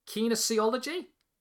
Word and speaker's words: kinesiology: who I believe was a professor of kines. Uh, how do you pronounce it? Kines, uh, kines, uh, ah, kinesiology. Is that how you pronounce kinesiology? – it? Kinesiology. Kinesiology. Sorry, thank you.